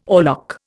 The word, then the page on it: oarlock: Indian